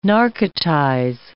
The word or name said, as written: narcotize